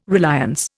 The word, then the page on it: reliance: Indian